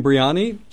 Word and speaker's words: biryani: Chicken biryani.